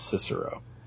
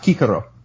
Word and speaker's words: Cicero: I'm just saying, what kind of education did you have that you would pronounce his name as Cicero? – The kind that's not American. – Dumb. – How's it pronounced? – Huh? Kikaro.